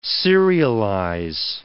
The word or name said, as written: serialize